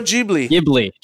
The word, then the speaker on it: Ghibli: G-H-I-B-L-I. – Oh, Studio Ghibli.